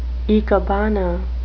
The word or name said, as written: ikebana